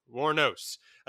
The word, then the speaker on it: Wuornos: It is based on the life of Aileen Woronos. I'm going to mispronounce that all night. Woronos. – Uh, War- Wuornos, a Daytona Beach prostitute who became a serial killer.